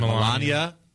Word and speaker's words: Melania: Is it Melania or Melania? – Melania? – Melania.